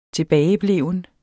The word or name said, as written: tilbagebleven